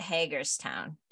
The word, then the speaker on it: Hagerstown: Maryland at Hagerstown. Did I say that correctly? – Yes, you did.